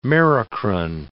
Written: merocrine

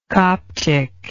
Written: coptic

